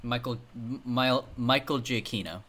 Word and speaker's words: Giacchino: This is the craziest take I've ever heard about this Batman movie, and it's that Mike Giacchino, is that how you say his name? – Michael Giacchino.